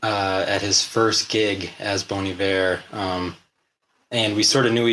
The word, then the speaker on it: Bon Iver: It means good winter. When Justin, the main dude, came up with the name, he just didn't know that winter was spelled with an H in French. – Oh, got that's it. – Funny. So, got it. – So he signed a letter to his friend, Good Winter, and just it looks like Bon Iver, but it's actually Bon Iver.